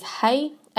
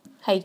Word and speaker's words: hei: K-E-I, and if you're writing it in an English way, would sound like K-A-Y. So that E-I at the, with K makes an A sound. Same with you, if you use hei, H-E-I, hei tauira ine, so these examples, hei. If you're writing it phonetically in English, it would be an A-Y. And, but yeah, often we think it should be K-A-I, which of course is kai, so that doesn't work out.